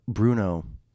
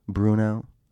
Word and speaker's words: Bruneau: But what I wanted to say in the certain parts was I was in Bruno. I think that's how you say it. It was spelled Bruneau. Bruno.